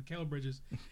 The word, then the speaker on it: Mikal Bridges: But you got guys like Michael Bridges and everything. I'm not sure how you pronounce his name. Mikal Bridges.